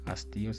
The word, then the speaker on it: hastío: Hastío Hastío se escribe con H al principio y ese hastío y acento en la I significa disgusto, interdicto o interdicto, interdicto, no interdicto, entredicho, prohibición de hacer o decir algo.